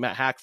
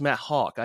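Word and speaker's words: Matt Haack: I even thought Matt Hawk, I was pronouncing it Matt Hack forever. And then the crowd thought they said Mike Hawk, which is obviously a pretty funny one when you say that pretty quickly. But it's pronounced Matt Hawk. I did not know that. It's not Matt Hack.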